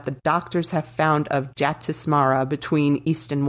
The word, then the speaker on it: Jatismara: During my research, I learned that the Sanskrit word, this is just kind of an aside, and I'm gonna spell it and then try to say it, J-A-T-I-S-M-A-R-A, Jatismara, means those who remember a past life.